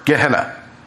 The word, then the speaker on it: Gehenna: And that name is Gehenna. That's your 10th term. If you want me to spell that for you, it's G-E-H-E-N-N-A. G-E-H-E-N-N-A, Gehenna.